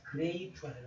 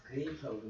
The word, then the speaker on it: grape: 맨 끝에 오는 게 묵음 때문에, 이 묵음인데 묵음 때문에 A가 애가 아니라 A로 바뀌어. 그래서 grape가 되는 거에는 작은 짝이 안 돼. 피아노는. 그래서 grape라고 하는 거야.